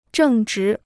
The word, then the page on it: 正直: zhèng zhí